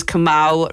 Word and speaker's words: Kamal: He said it how his name's Kamal rhymes with pal, and that was me just straight away.